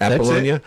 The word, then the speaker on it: Apollonia: Apollonia. – Yes, Apollonia.